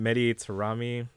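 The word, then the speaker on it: Mehdi Taremi: A player from Iran, Mehdi Tarami. That is how you pronounce it.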